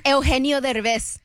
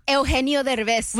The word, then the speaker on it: Eugenio Derbez: Speaking of Selena Gomez and this film, actor Eugenio Derbez.